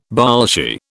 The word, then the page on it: Bolshie: British English